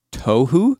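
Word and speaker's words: tohu (תֹהוּ): The word formless in Hebrew, it's pronounced tohu.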